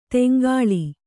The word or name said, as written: ತೆಂಕಳ